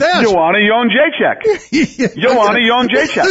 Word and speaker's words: Jędrzejczyk: Right, Joanna Jędrzejczyk.